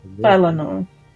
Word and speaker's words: Valinor: Was it Valoria? – Valinor. – Valinor. That's how you pronounce it.